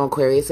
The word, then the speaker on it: Aquarius: Spelling, you know, Aquarius is AQUARIUS.